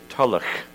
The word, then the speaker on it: Tulloch: But by the way, for all of those of you who don't know, Tulloch is a Scottish name that's best pronounced in the Scottish way, Tulloch, with the ch in the end, okay? And just like Buchanan, okay?